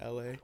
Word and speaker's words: LHA: It's spelled LHA, but pronounce it LA.